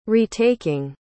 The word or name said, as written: retaking